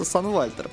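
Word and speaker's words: Sunwalter: Я могу сказать, что правильно читается Сан Вальтер, потому что я сказал уже, что Сан английская, Вальтер немецкая, значит немецкий кусок надо читать по-немецки.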